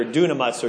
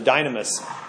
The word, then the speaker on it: dunamis: The Y is a upsilon. Later on, it started to be pronounced as an I sound. So dynamite or dunamis or dynamis is the word that is there.